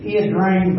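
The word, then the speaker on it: Ia Drang: I looked up how you say it. It's Yen Rain Valley.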